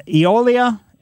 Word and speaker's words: Eolia: Rita lives in Eolia, Missouri. Is that true? Is that how you pronounce it, Rita? – That's it. You got it right. And not very many people do, but you did well.